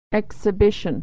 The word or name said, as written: exhibition